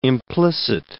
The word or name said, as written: implicit